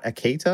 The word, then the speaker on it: Akita: Later that evening, Brown's pet dog, a white Akita, am I saying that right, dog people? – Yeah, it's like a Japanese breed.